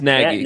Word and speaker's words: Nagy: It's Nagy.